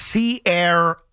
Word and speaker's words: Ciara: By the way, her real name is Ciara, and it's pronounced Sierra.